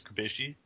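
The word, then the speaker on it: Cubelli: Sorry, I think two L's are usually pronounced with a J. So I said Thomas Kubeli, but his name's actually Thomas Kubeshi.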